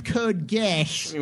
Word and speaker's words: Geass: Excuse me, it's pronounced Code Geass.